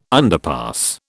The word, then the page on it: underpass: British English